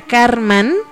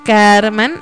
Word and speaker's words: karman: Aquí esto quiere decir que la palabra que estudiamos, que se pronuncia Karman, Karman, ahí está, que también es como un análisis interno, ¿no?